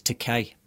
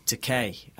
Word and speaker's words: Takei: He thought he didn't make the effort to learn, and he went one day, he was saying to him, "No, Bill, it's Takei, as in toupee." – Yeah. Rhymes with. – Yeah, rhymes with toupee.